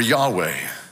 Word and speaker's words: Yahweh: It consists of four Hebrew letters, YHWH, and it's pronounced Yehovah or Yahweh.